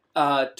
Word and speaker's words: Charaka: So first, can you tell me a little bit about what Charaka, did I say his name right? – Yes, yeah, Charaka.